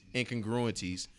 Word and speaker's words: incongruities: Meaning also helps us to reconcile incongruities. Am I pronouncing that right? – Help him out. – Yeah, incongruities.